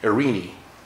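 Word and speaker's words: Irene (εἰρήνη): That's how you say it in Greek, Irene. And it's spelled E-I-R-E-N-E, Strong's word 1515.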